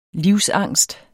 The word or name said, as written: livsangst